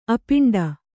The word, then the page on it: ಆಪಾಂಡು: āpāṇḍu